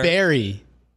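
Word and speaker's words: Barre: It's B-A-R-R-E. – Berry.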